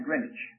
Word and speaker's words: Greenwich: Well, I was in the vicinity of a town called Greenwich. G-R-E-E-N-W-I-C-H, so W is silent. Greenwich.